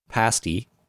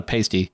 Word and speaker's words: pasty: It is. And you said it right. It's not a pasty. – I know. – Pasties are those things you put on nipples with tassels. – Mm-hmm. – Those are pasties. Pasty is food.